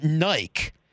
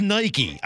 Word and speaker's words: Nike: It's not Nike. It's Nike.